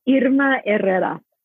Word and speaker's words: Irma Herrera: Irma Herrera.